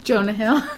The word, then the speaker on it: Jonah Hill: Jonah Hill. – Get his name right. He's not Jonas a member of the Jonas Brothers.